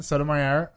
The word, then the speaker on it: Sotomayor: Sotomayor. What is wrong with me? Anyway, I love her, though. – Is it Mayor or Mayor? – Mayor. – Okay. So it's just as it's spelled.